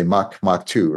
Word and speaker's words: Mach 2: And I should remind everyone that Mach 2 is spelled M-A-C-H-H-2, which is why you may have heard me erroneously refer to it occasionally as Mach H2, but you really just say Mach 2, right? – We do.